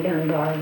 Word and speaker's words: Dunbar: And so he named it, he said, Dunbar, and it wasn't long till it became D-U-N-B-A-R, Dunbar. Two syllables, but not two words.